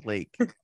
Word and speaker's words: Lake: Yeah, from Blake. yeah. – Uh, you said it wrong. It's actually Lake.